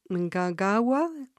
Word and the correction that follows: Mnangagwa: Mangagawa. It's close. Mnangagwa.